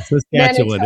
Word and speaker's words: Saskatchewan: Bear's extended canoe trips include the Yukon, Northwest Territories, Nunavut, Saskatchewan. I can never pronounce this word. Help me out here. I promise I can say it. – Yeah, Saskatchewan.